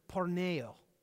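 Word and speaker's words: porneo (πορνεία): In the Greek, the original language of the New Testament was written, this is a word in the Greek name, it's pronounced this way, porneo, porneo.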